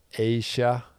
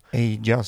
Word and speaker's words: Aja: Eh, många, jag tycker ju precis många andra att Asia uttalas det väl, även om det stavas Aja. Aja säger jag, men Asia.